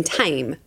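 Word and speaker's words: Thame: It's in Tame. Tame is spelt the same as the Thames.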